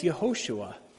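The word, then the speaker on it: Yehoshua (יהושע): His name was changed by Moses from Hosea, salvation, to Joshua, which is pronounced in Hebrew as Yehoshua, the Lord saves.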